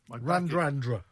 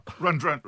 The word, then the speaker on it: Radradra: But my back is Semi Radradra. – Randrandra. – My back is Semi... Shut up. Can you speak Fijian or not? – Well, I can pronounce it, yeah. – Semi Randradra. – Randrandra. – Randrandra, right.